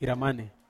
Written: ramane

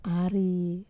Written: ari

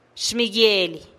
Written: xmiguielih

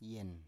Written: yen

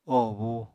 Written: oho búu

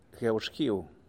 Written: Gueu Xguiu

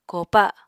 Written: kopa

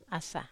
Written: asa

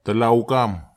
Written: talauk am

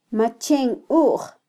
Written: machenuj

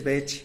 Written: betx

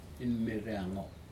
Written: inmereaga

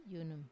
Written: yunum

